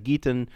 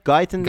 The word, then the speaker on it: Guyton: En nou, bij afwezigheid van één van deze of, of beide jongens, dan is Jalen Geaton, Guyton, hoe spreek je het uit? Guyton, Guyton volgens mij.